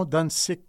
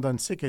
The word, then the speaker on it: Ahuntsic: Alors, l'un des deux hommes qui s'est noyé portait le surnom Huron Dansik, qui signifie « petit et frétillant », en raison, semble-t-il, de son agilité et de sa vivacité. Et c'est donc en sa mémoire que le nom Dansik